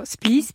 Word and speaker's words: Splice: Vous êtes la fondatrice de la marque Splice. On dit Splice ou on dit Splice ? – On dit alors Splice parce qu'on est en France, mais le terme, c'est un terme anglais à la base. – Alors, ça s'écrit S-P-L-I-C-E, je le précise.